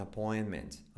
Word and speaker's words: appointment: Do you have an appointment? Appointment. Appointment. La T del medio te cae. Appointment. Do you have an appointment? Y la T casi no se dice final.